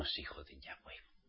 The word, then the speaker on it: Yahweh: Si tenéis verdaderamente el espíritu de Yahweh, clamar al Padre Yahweh, pronunciando bien su nombre, Yahweh, en el nombre de Yahshua, y veréis cómo el espíritu de Yahweh pondrá en contacto personalmente conmigo.